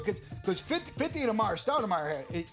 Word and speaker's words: Amar'e Stoudemire: Am I saying that right? Started Maya. – Who? Oh, Amari yeah, Stoudemire. – Amar'e Stoudemire. Fifty.